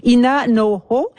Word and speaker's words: Ina Noho: Bon, là, je pour la prononciation, Ina Noho. Alors voilà, je l'ai dit comme il faut.